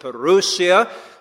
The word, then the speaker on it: parousia: Now that word, the coming of the Lord, you know it's the Greek word parousia. Some people pronounce it parousia.